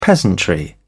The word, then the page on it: peasantry: British English